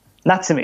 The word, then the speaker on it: Natsume: Is it pronounced Natsume? Oh. – Natsume, yeah.